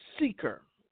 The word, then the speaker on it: Sychar: I'm just going to spell it C-Y-C-H-A-R. The enemy is a lie. Sychar. Thank you, Holy Spirit. It's pronounced Sychar, and it's near the ancient city of Shechem.